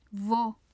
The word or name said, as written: vo